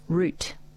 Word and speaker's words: route: D-A-N-C-E. How do you pronounce that? – Dance. – Dance. R-O-U-T-E. – Root.